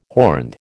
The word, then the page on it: horned: American English